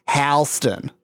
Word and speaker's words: Halston: His mother was really mad that she called himself Halston because the correct pronunciation of the family name is Halston.